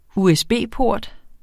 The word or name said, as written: usb-port